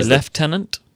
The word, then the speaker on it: Lieutenant: Well, we say Lieutenant. – Ah. – Spelled exactly the same. – Mm-hmm. – Pronounced Lieutenant instead of Lieutenant.